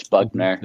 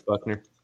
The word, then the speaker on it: Buchner: Because Ty Simpson and Tyler Buchner or Buckner or whatever the correct pronunciation of his last name is. Buckner. – I know. Yeah, it's Buckner. – It's Buckner.